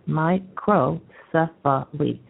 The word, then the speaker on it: microcephaly: They even tell you how to pronounce it. It's microcephaly.